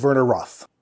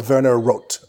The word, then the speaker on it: Werner Roth: Jay Gavin is Werner Roth. – Okay. – Or Werner Roth, I guess, is probably a closer to accurate pronunciation for the German name.